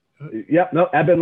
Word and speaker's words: Ebin: Ebin Latrimurti.